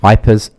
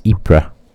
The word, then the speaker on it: Ypres: Wipers, Ypres, Ypres. However you want to pronounce it. – I would pronounce it Ypres, but some people pronounce it wipers. – The French pronunciation is Ypres.